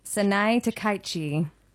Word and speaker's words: Sanae Takaichi: Sanae Takaichi. She has had... – Nice pronunciation.